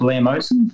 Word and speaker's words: bleomycin: One was the 3-cycle with, I think it's the chemical called bleomycin.